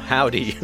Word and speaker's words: Hovvdy: Hovvdy, spelled H-O-V-V-D-Y, because that's how bands do it now. Hovvdy.